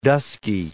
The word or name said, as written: dusky